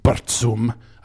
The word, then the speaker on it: Burzum: I'm talking about bands, of course, like Mayhem, Burzum, correctly pronounced Burzum, Dark Throne, Immortal, Emperor.